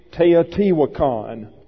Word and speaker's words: Teotihuacan: And I'll spell that for you. Teotihuacan. Pronunciation, Teotihuacan.